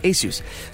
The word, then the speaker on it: Asus: A lot of people mispronounce that name. I think we get it right, Asus.